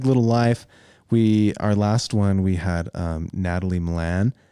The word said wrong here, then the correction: Malin: Malin. – No, dang it. Natalie Malin.